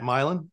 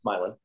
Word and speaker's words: Milan: It's not Milan, it's Milan. – Milan.